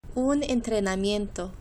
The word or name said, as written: un entrenamiento